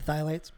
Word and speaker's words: phthalates: The phthalates.